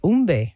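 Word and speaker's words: Humbe: I don't want to mispronounce the name. You said Umbe.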